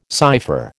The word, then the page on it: cypher: American English